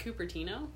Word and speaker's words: Cupertino: It's pronounced Cupertino? – Yeah. – Wow. – What did you think it was? – Cupertino? – Nope. – Okay. – I'm pretty sure it's Cupertino.